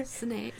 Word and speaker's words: Sinéad: Sinéad.